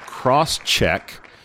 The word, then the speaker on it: Crosschq: Next up, we have CrossCheck. It's spelled CrossCheq.